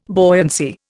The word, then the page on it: buoyancy: Indian